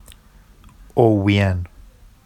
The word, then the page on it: owwijan: Vafsi